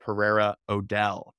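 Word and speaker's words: Pereira O'Dell: This is about you because you are a senior art director at Pereira Odell.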